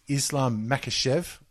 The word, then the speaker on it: Makhachev: It's Islam Makhachev.